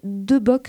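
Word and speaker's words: De Boeck: Il a publié dernièrement un ouvrage intitulé « 10 questions sur l'immigration » chez De Bock Supérieur. J'espère que je prononce bien. Éric Savarez, bonjour. – Bonjour. – J'ai bien prononcé la maison d'édition ? – Tout à fait.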